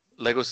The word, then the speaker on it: Lagos: If I'm butchering the pronunciation of that, please correct me. – No, you did okay. – Lagos is good.